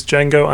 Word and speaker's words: Django: And today's film, episode 51 of Pod Me If You Can, is Django Unchained. The D is silent.